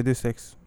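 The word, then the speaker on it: Deus Ex: اسمها دوس اكس ايوه ديوس اكس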